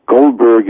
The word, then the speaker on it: Goldberg: Goldberg is B-U-R-T-O-N is my first name, and my last name is G-O-L-D-B-E-R-G.